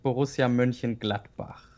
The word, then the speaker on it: Borussia Mönchengladbach: I would pronounce it Borussia Mönchengladbach.